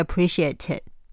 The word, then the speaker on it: appreciate it: 注意连音，appreciate it。那你要注意这句话的最后一个 it 的 t 没有发音，I really appreciate it。